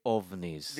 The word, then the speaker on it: OVNI: In Venezuela, we say OVNIs. – Because it's... – What? – Objeto volador no identificado. So it's OVNI. – Spell it. – O-B, like violet, N-I.